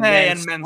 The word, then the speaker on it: Mansoor: How do you pronounce it? Mace and Mansoor, or is there like a different way? – Mace and Mansoor.